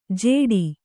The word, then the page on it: ಜೇಡಿ: jēḍi